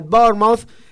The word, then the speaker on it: Bournemouth: En el fútbol internacional, el Manchester City ayer empató 1-1 frente al Bournemouth, quedando así, la correcta pronunciación del club más grande del sur de Inglaterra.